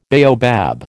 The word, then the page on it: baobab: American English